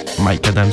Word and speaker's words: MICADAM: Mike Adam, c'est comme ça que vous prononcez. Mike Adam, si si.